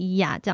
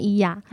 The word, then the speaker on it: Oia: 对，因为我们就是在开业前花了一点钱去希腊自助旅游，然后那个 Santorini 小岛上的一个小镇叫伊亚，就是现在伊亚动物园伊亚，它的英文是 O I A，然后我们就觉得哇好酷哦 O I A，但是它念伊亚这样子，乡巴佬的感觉。 – 发音就是念一啊。 – 对，它就是念伊亚。 – 他 O 不发音吗？ – 对啊，它 O 不发音。